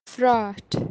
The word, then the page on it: phraath: kashmiri